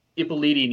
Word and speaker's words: Ippoliti: But the cool thing about Navajo Joe is he got Epitoli to shoot it, which also he shot The Great Silence. – Oh, wait, I can do this. Silvano Ippoliti. There we go. – Ippoliti. That's how you say it.